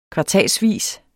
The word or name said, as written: kvartalsvis